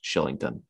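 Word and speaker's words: Kylington: It's spelled Killington, but it's pronounced Shillington.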